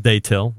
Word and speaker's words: Datil: Now, Greg, you'll have to correct my pronunciation. Day-till pepper hot sauce. Is that right? Day-till. – I've never heard of it. – D-A-T-I-L. – Yeah, we'll go with day-till.